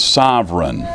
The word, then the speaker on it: sovereign: It's spelled, in the English, we spell it S-O-V-E-R-E-I-G-N, okay? A pronunciation, you know how it'll have the phonetic pronunciation in a dictionary? That is S-U-V, apostrophe, emphasis on that syllable, right, syllable. I had a teacher teach me that, bad English, E-R-A-N, okay? And so S-U-V, apostrophe, E-R-A-N.